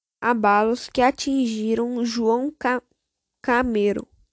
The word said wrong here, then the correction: Câmara: Câmara, Câmara, João Câmara.